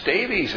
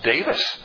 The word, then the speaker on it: Davies: But in Wales, Davies is not pronounced Davies. It's pronounced Davis.